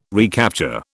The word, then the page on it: recapture: British English